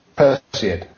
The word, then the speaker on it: Perseid: Because I'm not sure how to pronounce the name of the star thing that this guy's on about. – How soon will we be able to observe the Perseid shower? – Perseid. – Oh, Perseid, yeah. I was just too nervous about mispronouncing it, sorry.